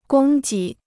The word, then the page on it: 供给: gōng jǐ